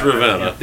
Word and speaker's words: Ravenna: I think it's Ravenna or Ravenna. – It's Ravenna.